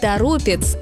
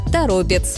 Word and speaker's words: Торопец: Но «торопец» – исключение, и именно поэтому он регулярно становится ловушкой для произношения.